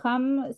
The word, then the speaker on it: ham: Y este es como ham, si yo les dijera de jamón, pero cuando lo vemos escrito, se escribe H-A-M y el ham se pronuncia la H como si fuera en el idioma inglés.